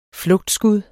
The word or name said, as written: flugtskud